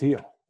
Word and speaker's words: Gil: Heel's his name. I've been calling him Luis Gil on the podcast for like a month. – I think it's pronounced Heel.